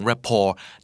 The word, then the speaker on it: rapport: สวัสดีครับคุณกําลังฟังคํานี้ดีพอดแคสต์สะสมศัพท์กันวลนิดภาษาอังกฤษแข็งแรงคำนี้ดีในวันนี้ขอเสนอคำว่า RAPPORT ครับอ่านว่า rapport หน้าตาเหมือน rapport นะครับแต่ว่าจริงๆคือ rapport มาจากภาษาฝรั่งเศสเนาะคำนี้แปลว่าความถูกคอกันความถูกชะตากันสายสัมพันธ์อันดีไมตรีจิตนะครับมีความใสใยกันมีอีก 8 คำที่ผมว่าสามารถอธิบายได้ถึงความหมายของ rapport